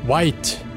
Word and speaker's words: Wight: Começando então com Inumano, White, que se escreve em inglês W-I-G-H-T.